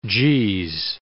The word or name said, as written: jeez